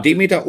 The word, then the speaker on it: Demeter: Demeter und Bio äh, genau. zertifiziert, ist das richtig ja. ausgesprochen? Ja. Okay.